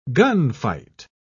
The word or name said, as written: gunfight